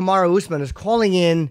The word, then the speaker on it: Kamaru Usman: Kamaru Usman.